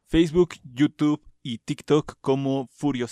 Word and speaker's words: Furyo: Furio Sensei, se dice Furio, se escribe F-U-R-Y-O Sensei con S.